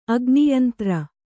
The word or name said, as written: ಅಗ್ನಿಯಂತ್ರ